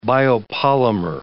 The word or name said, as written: biopolymer